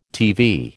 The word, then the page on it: TV: American English